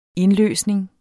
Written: indløsning

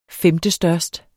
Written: femtestørst

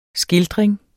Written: skildring

